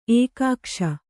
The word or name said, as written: ಏಕಕೋಶ